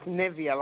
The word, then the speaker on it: Nivea: Am I pronouncing it right, Navia? – It's Nivea, like the lotion, Nivea.